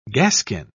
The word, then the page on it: gaskin: British English